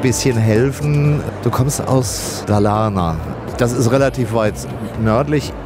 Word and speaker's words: Dalarna: Dalarna. – Gut, also wir merken uns auf der ersten Silbe. – Genau, Dalarna, das stimmt.